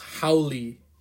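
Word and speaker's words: haole: It's it's pronounced Howley. – Okay. – Howley. – That's how they say it over here.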